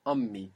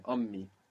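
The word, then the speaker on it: Ommi (أمي): Ommi. And there's two M's here. Ommi. – Omi. – Yeah, Ommi.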